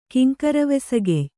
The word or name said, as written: ಕಿಂಕರವೆಸಗೆಯ್